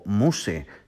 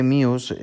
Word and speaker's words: Muse: Eh, el título de este podcast es Let's Talk About Muse o Muse, si lo leyéramos literalmente o lo pronunciáramos literalmente en español. Pero vamos a nombrarlo Muse, que suena muchísimo mejor, si os parece.